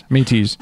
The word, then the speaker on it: Métis: Métis.